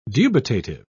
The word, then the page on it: dubitative: British English